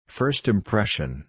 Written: first impression

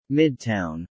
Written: Midtown

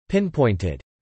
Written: pinpointed

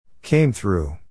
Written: came through

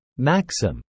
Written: maxim

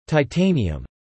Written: titanium

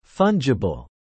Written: fungible

